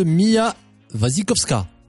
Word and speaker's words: Mia Wasikowska: Belle prestation de Mia Wasikowska.